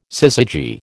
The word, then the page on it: syzygy: American English